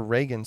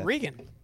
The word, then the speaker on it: Regan: Is it Regan or Reagan Smith? – Regan. – Okay. I've heard people pronounce it different ways.